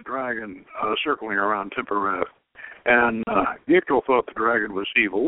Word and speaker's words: Gichtel: And as I said, you can look at Gitchell's Gichtel. I keep mispronouncing it Gitchell. It's Gichtel.